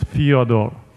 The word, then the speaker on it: Fyodor: Well, the Russian way to pronounce is Fyodor.